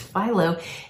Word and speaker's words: phyllo: It's phyllo.